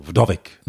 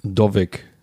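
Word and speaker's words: Wdowik: Also schon das W mit so irgendwie so mithauchen. Vdovic. – Vdovic. Okay. Vdovic. Spricht der Kollege Bartlomei Vdovic, spricht er Deutsch?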